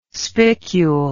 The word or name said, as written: spicule